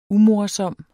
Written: umorsom

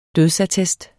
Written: dødsattest

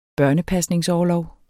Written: børnepasningsorlov

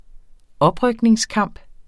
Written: oprykningskamp

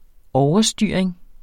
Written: overstyring

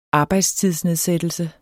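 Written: arbejdstidsnedsættelse

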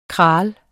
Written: kraal

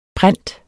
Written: prent

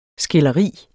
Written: skilderi

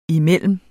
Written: imellem

